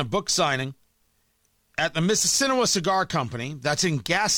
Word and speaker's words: Mississinewa: M-I-S-S-I-S-S-I-N-E-W-A. Mississinewa.